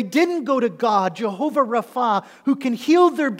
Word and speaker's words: Rapha: This is the name Jehovah Rapha, or Rofi, but I think it's best pronounced Rapha, Jehovah Rapha.